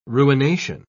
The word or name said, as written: ruination